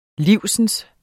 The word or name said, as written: live